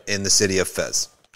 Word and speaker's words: Fez: City of Fez. Pronounced in Arabic, Fez.